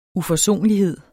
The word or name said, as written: UFORSONLIGHED